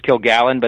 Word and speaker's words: Kilgallen: Yeah, it's actually, some people have pronounced it that way. We use Kilgallen, but that's Kilgallen. Close enough.